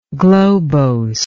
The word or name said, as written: globosity